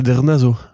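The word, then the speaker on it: Drnaso: Michael Sarnowski, euh, qui a fait Pig avec Nicolas Cage, qui est vachement cool, réalise l'adaptation des comics Sabrina euh, de Nick Dranzo. Ah, t'as réussi à bien le dire. Ouais. Non, c'est Dernazo. Dernazo. Dernazo.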